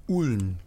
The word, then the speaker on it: Ulm: Wiesbaden, Münster, and where you are now, and Ulm. Am I saying that right? – Ulm, Germany, which is where in the south.